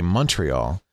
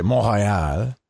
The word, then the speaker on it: Montréal: Yeah, because I noticed the Anglophone Canadians say Montreal. French, of course, say Montréal. And Americans say Montreal.